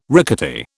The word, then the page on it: rickety: British English